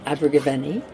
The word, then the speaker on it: Abergavenny: It's so exciting to be here in Abergavenny.